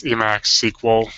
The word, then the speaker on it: EmacsQL: And that's something I resolved with EmacsQL, or EmacsQL. I don't know how you pronounce that out loud. I never say it out loud. it's Emacs SQL.